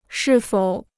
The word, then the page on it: 是否: shì fǒu